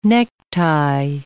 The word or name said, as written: necktie